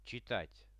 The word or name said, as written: chitat